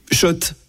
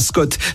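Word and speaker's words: Schott: Oui, Shot et pas Scott, ça fait des années qu'on prononce mal la marque.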